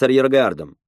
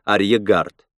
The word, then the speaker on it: арьергард: Если мы сейчас двинемся в тыл основной группе жуков, завязнем в схватке с арьергардом». – Я подумал, что Фантом — один из немногих известных мне людей, которые произносят слово «арьергард» правильно, а не как «арьегард».